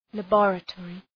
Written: laboratory

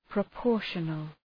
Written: proportional

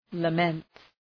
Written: lament

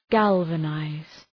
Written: galvanize

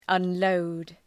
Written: unload

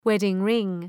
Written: wedding-ring

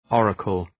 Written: oracle

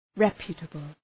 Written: reputable

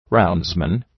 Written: roundsman

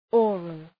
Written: oral

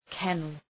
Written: kennel

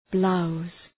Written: blouse

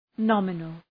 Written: nominal

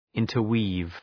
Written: interweave

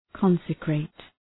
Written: consecrate

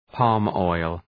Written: palm oil

